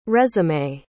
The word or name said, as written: résumé